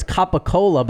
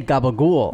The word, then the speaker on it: capicola: Well, you know what? It's capicola, but they say gabagool.